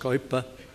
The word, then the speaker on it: Kuyper: It's said of the famous Abraham Kuyper, or rightly pronounced Kuyper,